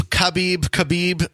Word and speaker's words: Khabib: You were talking about how to pronounce Habib. – Habib's name. – Khabib. There's like, like I was telling you, the K or not the K,